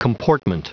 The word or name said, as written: comportment